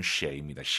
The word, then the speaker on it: 谁: 정확한 발음은 쉐이입니다.